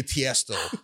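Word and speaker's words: Tiësto: DJ Tiesto.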